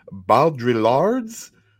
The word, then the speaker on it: Baudrillard: Jean Baudrillard's City. You don't pronounce the L's. Baudrillard.